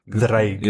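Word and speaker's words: Ddraig: Oh, it's a voice D? The. Yeah, double D is... – Grim U Dreig. – Dreig. – Yes. Well, it's notoriously difficult Welsh because, like, yeah, letters, like, change pronunciation depending on other letters around them as well. – No. It is a very phonetic letter. It's just, like, one of the letters is two Ds.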